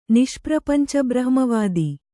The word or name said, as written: ನಿಷ್ಪ್ರಪಂಚಬ್ರಹ್ಮವಾದಿ